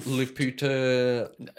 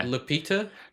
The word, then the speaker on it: Lupita: Oh, oh, Lupita. You've said that wrong already. Stop there. Have I? L- yeah. Lupita.